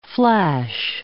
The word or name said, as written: flash